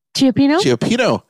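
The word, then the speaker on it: cioppino: They also have great, the seafood, Chapulpino. Did I say that right? No, I probably didn't. Please help me pronounce that. It's a Chiapino. – There you go. Chiapino rhymes with Filipino.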